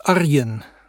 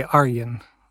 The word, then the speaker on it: Arjun: It's Arjun. That would be completely perfect Dutch. But even in the Netherlands, some people say Arjun.